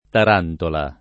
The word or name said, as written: tarantola